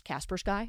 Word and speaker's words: Kaspersky: We saw what happened with Kaspersky, which apparently that's pronounced Kaspersky, and I've been pronouncing it wrong.